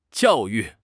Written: jiao4 yu4

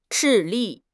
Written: 致力

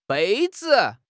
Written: bei1 zi5

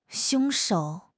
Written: 凶手

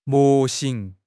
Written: 模型

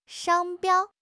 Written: 商标

